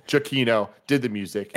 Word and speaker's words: Giacchino: in this one was by Michael Nick Giacchino. And now we have looked into it. We went to Twitter. – Mm-hmm. – His Twitter in his bio says, My last name is pronounced Giacchino. So Giacchino.